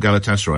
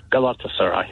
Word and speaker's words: Galatasaray: Sorry, I always call them Galatasaray. I'm mispronouncing it, am I? – No, it's, yeah, Galatasaray.